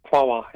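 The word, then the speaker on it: Quaoar: So in terms that we can understand, you found a ring around this planet Quawar. Is that how you pronounce it? – Quawar, yeah.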